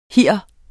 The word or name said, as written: hir